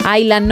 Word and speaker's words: Island: Walk, en Island no pronuncian la S, y yo diría que en la música folk no dicen folk.